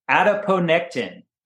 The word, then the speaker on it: adiponectin: And it secretes a very beneficial molecule called adiponectin. Adiponectin. And you can Google adiponectin, which is spelled just like it sounds, A-D-I-P-O-N-E-C-T-I-N.